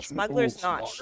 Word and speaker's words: Smuggler's Notch: Smuggler's Notch. – Smuggler's Notch. – Rhymes with Scotch.